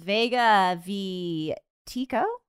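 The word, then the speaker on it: Vega v. Tekoh: This one is Vega v Tekoh? – Mm-hmm.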